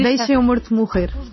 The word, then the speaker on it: Deixemo Morto Morrer: In Portuguese, it's very hard to pronounce Portuguese, but it's almost Portuguese. – No, you Deixemo Morto Morrer.